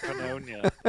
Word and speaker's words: pneumonia: What are these crazy words like pneumonia? Starts with a P. What's going on here? – I pronounce the P when I... – Pneumonia? – Pneumonia. And if I use a knife, it's a knife. – It's a knife. Okay. – Why the silent K?